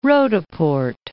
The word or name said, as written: Roodepoort